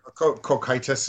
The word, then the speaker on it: Cocytus: Cocytus.